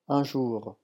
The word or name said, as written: un jour